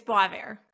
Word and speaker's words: Boisvert: It's Boisvert.